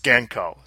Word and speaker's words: Genco: I've heard Steve Kordek, who actually worked there, he said it was Genco.